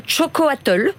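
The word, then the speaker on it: xocolatl: Alors, on commence par quoi C'est quoi le début de l'histoire quand même Le début de l'histoire, je vais essayer de vous, le présenter, de vous le prononcer correctement parce que c'est de l'aztèque. – C'est compliqué. – Ça s'appelle Chocoatl.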